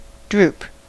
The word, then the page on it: droop: /ˈdɹuːp/; [ˈdɹuːp]